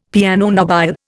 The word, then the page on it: piano nobile: Indian